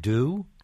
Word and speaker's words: dew: And how do you pronounce D-E-W? Do? – Do. – Okay. As opposed to D-O? – No, they're pronounced the same way in Iowa. – And N-E-W-S and N-O-O-N-O.